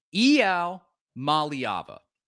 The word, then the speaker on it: Iamaleava: And this is how we've been saying, Iamaleava, something like that, along those lines. And this is apparently how his name is pronounced. – Here with five-star quarterback Nico Iamaleava. Nico, you... What? Excuse me? He said Iamaleava.